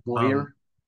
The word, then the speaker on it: Bowyer: I think it's, I thought it was Bauer, but it's spelled B-O-W-Y-E-R. Bowyer? Bowyer? – Yeah, Boyer. – Boyer? Maybe Boyer.